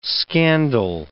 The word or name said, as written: scandal